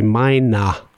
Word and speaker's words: meiner: So we say meiner with er at the end. It sounds like a when you pronounce it. So we say meiner.